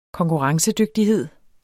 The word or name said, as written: konkurrencedygtighed